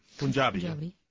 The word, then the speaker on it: Punjabi: Did I say that right? He is Punjabi. – Punjabi.